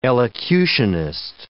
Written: elocutionist

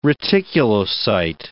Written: reticulocyte

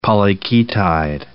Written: polyketide

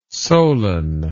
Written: Solon

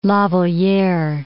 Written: La Valliere

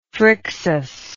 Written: phrixus

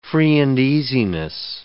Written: free-and-easiness

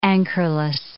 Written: anchorless

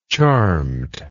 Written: charmedly

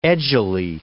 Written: edgily

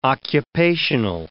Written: occupational

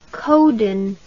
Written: coden

